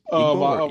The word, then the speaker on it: Igbo: So it's spelled I-G-B-O. – Right. – And it's actually Igbo. That's how you say it, Igbo.